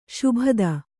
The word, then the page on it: ಶಾಬ್ದ: Kannada